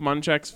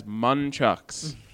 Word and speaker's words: Munchak: A pair of nunchucks with Mike Munchak's face on them, branded as Munchucks. – Because he kept mispronouncing Mike Munchak's name in his press availability. He said Munchuck.